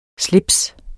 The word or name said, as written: SLIPSENES